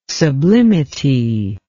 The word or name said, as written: sublimity